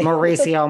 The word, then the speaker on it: Mauricio: Did I say the were Oh, I said the name wrong. – Okay, Mauricio, Maurice, Mauricio, same thing.